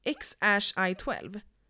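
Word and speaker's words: X Æ A-12: Och det uttalas X i 12.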